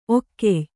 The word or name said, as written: ಒಕ್ಕೆಯ್